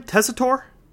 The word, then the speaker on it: Tessitore: Announcers for this game, Joe Tessitore. How do you say his name? Tessitore? – Tessitore. – Okay, Tessitore, Booger McFarlane, and Lisa Salter is the field announcer.